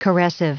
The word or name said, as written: caressive